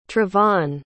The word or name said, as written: trevon